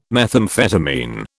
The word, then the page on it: methamphetamine: British English